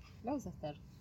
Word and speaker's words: Gloucester: Bueno, mi caso es La Casa del Horror de Gloucester. – Bien, bien pronunciado, no sabemos. – Perfectamente. – Será Gloucester, por ahí. – Gloucester.